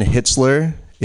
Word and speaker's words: Hitzler: Um, if you didn't happen to catch my name, it is Ryan Hitzler. It is spelled H I T Z L E R.